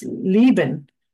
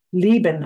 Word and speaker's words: Leben: And if you read it backwards, It's Leben. Leben. I know how to say Leben. I know I said that correctly, and I believe that means life, which is basically what the song is about.